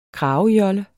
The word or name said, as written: kragejolle